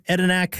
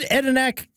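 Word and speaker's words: Adanac: Google Street View doesn't get you anywhere near Edinac.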